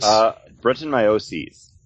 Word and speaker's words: Brettanomyces: How do you say those three words? – Brettanomyces.